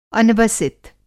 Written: अनवसित

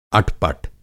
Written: अटपट

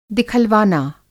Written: दिखलवाना

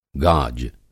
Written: गाज

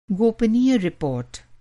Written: गोपनीय

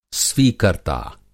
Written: स्वीकर्ता

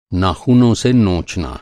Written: नोचना

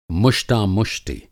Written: मुष्टामुष्टि

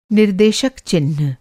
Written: निर्देशक चिह्न

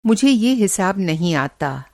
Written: हिसाब

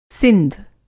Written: सिंध

